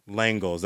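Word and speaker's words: L'Engle: Langles. That's how you say it.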